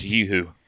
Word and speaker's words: Yoo-hoo: Kind of like Yahoo. It's not chocolate milk. It's a chocolate drink. – It's pronounced Yoo-hoo.